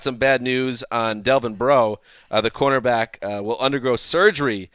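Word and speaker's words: Breaux: Rap Sheet reported that the fracture was discovered when an independent doctor read Bro's x-ray.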